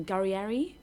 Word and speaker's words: Guerrieri: can't say his name, Guerrieri. – That sounds right.